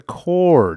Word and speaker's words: corps: Well, it's the core, dude. – But it's the abbreviation C-O-R-P-S, so... – Yeah, but the P is silent.